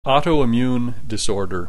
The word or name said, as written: autoimmune disorder